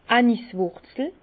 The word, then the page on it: d' Aniswùrzel: Bas-Rhin; Herrlisheim